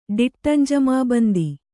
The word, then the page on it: ಡಿಟ್ಟಂಜಮಾಬಂದಿ: diṭṭam j'amābandhi